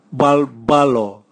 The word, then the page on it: bwabwalo: Raga